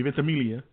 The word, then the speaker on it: Ventimiglia: Jack, Milo Ventimiglia. Excuse me, Ventimiglia. Because the G is silent, so get rid of it.